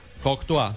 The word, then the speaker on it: Courtois: Courtois. – Y el nombre, Thibaut. – Ti. – Sí, Thibaut. – Thibaut. – Thibaut. – Thibaut. – Thibaut Courtois.